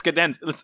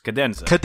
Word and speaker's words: Cadenza: It's Cadenza. It's like the musical notations. Cadenza.